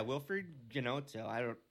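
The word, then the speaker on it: Wilfried Gnonto: Yeah, Wilfred Ginotto. That pronunciation's probably wrong. – Yeah, it sounds pretty good.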